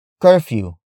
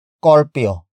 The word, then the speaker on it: curfew: Some Tagalog speakers would pronounce the word as corpio. Corpio. Corpio.